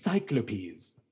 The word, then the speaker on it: Cyclopes: That's how you pronounce it. Cyclopes. Cyclopes.